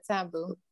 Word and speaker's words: Sambu: Am I pronouncing that last name correctly? S-A-M-B. – Yes, you got it, Sambu.